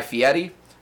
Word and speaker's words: Fieri: I'm sorry, I have to correct you. It's Guy Fieri. That's how you pronounce his last name.